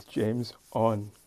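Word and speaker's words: JamesOn: His name is spelled capital J A M E S capital O N. It's James On.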